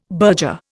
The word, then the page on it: burger: Indian